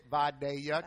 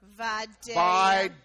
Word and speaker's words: Vidalia: And by Real Sweet Onions by Schumann Produce, they are the leaders in Vidalia, and that's how you say it, Vidalia. Can we all say it together? – Vidalia. – Vidalia.